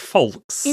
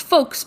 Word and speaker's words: folks: this person, contestant number two, pronounces the L in folks. So they say like folks. – In folks. – Yeah. – Interesting. I would say that this is an internet red beige flag. I've heard you pronounce folks before, and I don't recall an L.